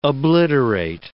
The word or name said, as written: obliterate